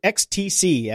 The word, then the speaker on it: XTC: Yeah, I think it's funny that you pronounce it XTC as it's written, mm-hmm. but shouldn't it just be ecstasy? – Everybody's always called them XTC.